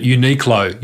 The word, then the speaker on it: Uniqlo: So there's a clothing brand called, it's hard to pronounce, but Uniqlo, U-N-I-Q-L-O.